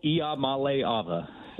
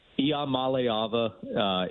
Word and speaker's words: Iamaleava: Nico Iamaleava. And I do think it's easier to say just in isolation or trying to slow down, but Iamaleava is the pronunciation, is best I can tell, inaccuracy, Iamaleava.